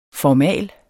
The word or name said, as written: formal